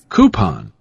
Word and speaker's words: coupon: Coupon. – Coupon. – It's coop. – Oh, coop. Not cube. It's coop. – It's coupon.